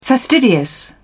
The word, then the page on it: fastidious: British English